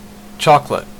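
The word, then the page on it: chocolate: Canadian English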